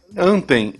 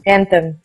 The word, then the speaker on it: Anthem: É, é, vamos lá, de novo minha pronúncia. É Anthem, Anthem. – Anthem.